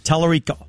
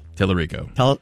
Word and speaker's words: Tallarico: I think that's how you pronounce it. Tallarico. – Tallarico. – Tallarico.